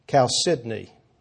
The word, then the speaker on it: chalcedony: The first foundation stone was jasper, the second, sapphire, the third, chalcedony. One way to pronounce it, I think the proper pronunciation is chalcedony.